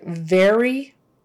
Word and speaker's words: very: Especially like very, if you pronounce it berry, that's a completely different word in English.